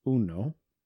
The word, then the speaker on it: Uno: And the game of Uno is supposed to teach kids numbers. – How do you say that? Say the game of what? – Uno. – You kept saying you know.